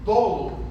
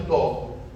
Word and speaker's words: dolo: Dolo, a gente no Brasil convencionou chamar de dolo, com O aberto. Os portugueses chamam de dolo. Então não há nenhum erro em falar dolo, mas fica parecendo erro porque as pessoas não conhecem a língua portuguesa.